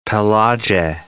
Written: Pelagie Islands

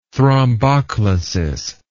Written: thromboclasis

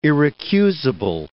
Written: irrecusable

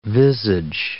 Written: visage

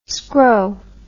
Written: scrow

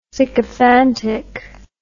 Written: sycophantic

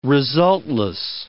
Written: resultless

